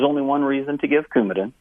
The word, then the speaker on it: Coumadin: And one that I thought was being called into question about what it signified about her is that she's on lifelong, and I believe it's pronounced Coumadin, C-O-U-M-A-D-I-N. And that's something that I thought that you were reading something into in terms of what that signifies about her health. – Pronounced Coumadin, and Coumadin is a very important medication.